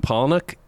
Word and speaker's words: Palahniuk: I forget how to pronounce Chuck Palahniuk.